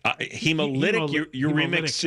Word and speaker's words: hemolytic: How's it pronounced? – Hemolytic uremic. – Yeah, you're right. Hemolytic.